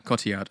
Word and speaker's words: Cotillard: That's the one, yeah, Cotillard. – Cotillard, excuse me, yes, my pronunciation.